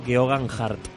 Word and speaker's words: Geoghegan-Hart: El apellido lo puedes decir tú, por favor. – Tao Geoghegan-Hart.